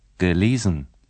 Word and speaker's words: gelesen: Lesen, gelesen.